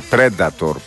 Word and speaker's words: Predator: Μου λέει «Predator» προφέρεται.